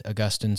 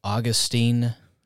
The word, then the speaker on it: Augustine: Saint Augustine, some pronounce it Augustine, and that's a mistake. It's Augustine.